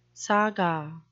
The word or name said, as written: saga